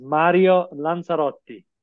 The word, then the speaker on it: Mario Lanzarotti: So the Italian pronunciation is Mario Lanzarotti.